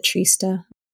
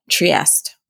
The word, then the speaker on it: Trieste: Did I pronounce that correctly? Is it Trista? – Trieste.